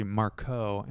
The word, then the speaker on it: Markow: My name is pronounced actually Marco, and people just never see that K-O-W coming.